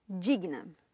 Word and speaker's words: digna: And it actually has three syllables. Digna. Digna. – Yeah, that's right. – But in Spanish, you'd say? – Digna. – Digna. And that, clearly there are only two syllables in that word.